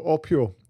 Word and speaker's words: AUPEO: So Orpio, which is AUPEO, so you can all have a go at pronouncing it.